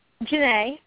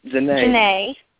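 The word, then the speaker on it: Janée: Jane. Did I say it right? – Janae. – Janae.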